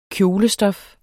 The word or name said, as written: kjolestof